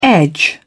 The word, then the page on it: edge: British English